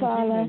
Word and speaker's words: Vala: So, Valva, is that how you pronounce it? She's 17 Vala.